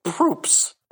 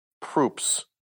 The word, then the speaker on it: Proops: Proops. – Excellent. – Yep. Proops.